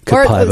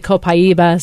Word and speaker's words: Copaiba: And I was wondering, and it seems to me like there's a new thing that I've never heard of called Copaiba. Am I saying that right? – Yeah. Copaiba. Or some people say Copaiba, some say Copaiba.